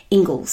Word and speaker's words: Inglis: It is not pronounced, it looks like English, but it's actually pronounced Ingles.